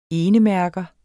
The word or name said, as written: enemærker